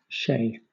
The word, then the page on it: chez: British English